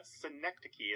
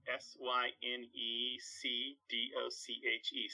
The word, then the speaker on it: synecdoche: Oh yeah, synecdoche. It's a strange spelling. S Y N E C D O C H E. Synecdoche. – Oh my it's gosh, I've been misspelling. I mean, mis mispronouncing it my whole life.